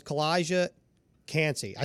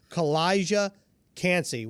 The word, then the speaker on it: Calijah Kancey: And the guy I want to talk about is Calijah Kancey. I say that right? – You got it. – Man, that is a tongue twister. Calijah Kancey.